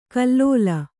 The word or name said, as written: ಖಲಲು